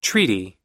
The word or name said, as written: treaty